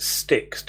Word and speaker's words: Styx: Your pronunciation threw me slightly there because I would pronounce it sticks to rhyme with Nix.